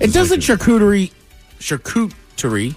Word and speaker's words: charcuterie: You pronounce it like this. Charcuterie. – Charcuterie. – Charcuterie. Very good. – Oh, the mamma mia. – It's not cute. It's charcuterie.